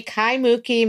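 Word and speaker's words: Kaimukī: And you were also, okay, so I'm going to hear this boast, 2020 Kai Mukki McKinley Roosevelt Complex Area Teacher of the Year. And I think I pronounced that one right. – You did amazing, yes.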